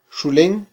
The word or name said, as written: xulen